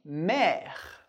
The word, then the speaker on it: mer: O R a gente pronuncia, o E que vem depois a gente não pronuncia, o R da garganta que a gente vai ver em outra aula, e o E accent grave, sempre bem aberto, por isso, mer. Mer.